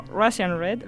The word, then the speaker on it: Russian Red: Tras los nuevos exitosos I Love Your Glasses y Fuerteventura, Lourdes Hernández, más conocida como Russian Red, ¿lo he dicho bien, Golo? – Sí, sí, perfecto.